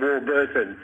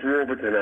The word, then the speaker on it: Warburton: By the way, I don't think you'd pronounce it Warburton. It's Warburton, I think you'd pronounce, Craig.